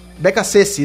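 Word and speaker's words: Beccacece: Porque o Beccacesti, é assim que se pronuncia, né? Toda vez eu me enrolo. – Beccacesti, exatamente.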